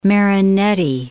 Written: Marinetti

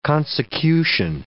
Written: consecution

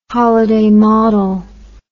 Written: holliday model

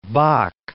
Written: Bok

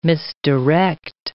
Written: misdirect

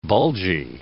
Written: bulgy

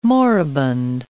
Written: moribund

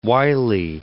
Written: Wiley

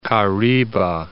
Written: Kariba, Lake

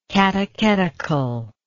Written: catechetically

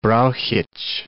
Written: Brauchitsch

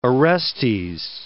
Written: Orestes

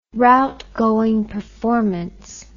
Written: route going performance

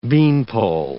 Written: beanpole

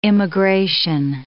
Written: immigration

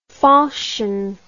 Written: fauchon

